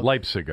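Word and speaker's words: Leipsic: Leipzig, I think it's pronounced. – Leipzig? – Yeah.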